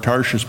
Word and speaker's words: Tarshish: People have laughed at the way I pronounced that word, Tarshish, but that's how you say it, T-A-R-S-H-I-S-H.